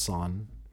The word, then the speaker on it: Sorn: My uncle was over for Thanksgiving, and his name is Son, S-O-R-N. – Okay. – But pronounced Son.